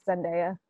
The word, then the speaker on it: Zendaya: So Michelle, played by the fabulous Zendaya, am I saying her name right? Is it Zendaya or Zendaya? – Zendaya is Michi. It's Zendaya.